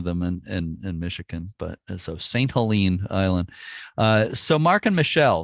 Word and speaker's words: St. Helena: From now on, I'll say St. Helene, the way you pronounce it.